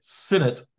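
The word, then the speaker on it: Sinnett: We had a debate earlier about what Reed Sinet's name is pronounced. Yeah, apparently it's Sinet, like Tony Dorsett.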